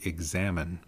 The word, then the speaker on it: Examen: For many years, or many centuries even, it has been referred to as the Examen. That is spelled E-X-A-M-E-N, examine, not the word examine, as in to look at something or observe it, but examen.